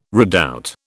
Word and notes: redoubt: British English

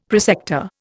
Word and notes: prosector: Indian

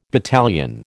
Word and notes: battalion: American English

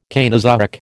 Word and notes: Cainozoic: American English

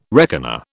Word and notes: reckoner: British English

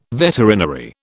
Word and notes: veterinary: British English

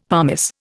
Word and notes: pumice: Indian

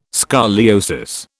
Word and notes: scoliosis: British English

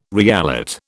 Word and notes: reallot: British English

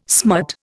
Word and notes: smut: Indian

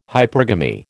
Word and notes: hypergamy: American English

